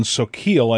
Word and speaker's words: Soquel: That's K-Y-A-A in Soquel, I believe it's pronounced, S-O-Q-U-E-L, California.